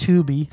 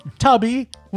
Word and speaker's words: Tubi: Yeah, we ended up getting off Tubi or Tubby, or I'm assuming it's Tubi. – Tubi. Stop mispronouncing stuff when people were trying to direct people to it. – Tubi. Well, Tubby. Would no. – be with two B's.